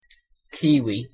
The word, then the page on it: kiwi: English